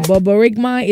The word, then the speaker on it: borborygmi: That is baboregmi. It is spelled B-O-R-B-O-R-Y-G-M-I. Baboregmi. Bobo, no. G she inside baboregmi. B-O-R-B-O-R-Y-G-M-I. Baboregmi.